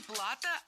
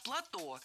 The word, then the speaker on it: плато: А после, через некоторое время, через несколько тысяч лет, океан отступил, и осталось, получается, такое песчаное плато, которое было спрессовано. – Не плато, а плато. Слово это тоже французское и тоже сохраняет ударение на последнем слоге.